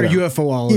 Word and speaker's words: ufologist: So I'd imagine that like the ufologist, why am I saying that wrong? – Ufologist? – It's ufologist or UFOologist? – You can say it either way.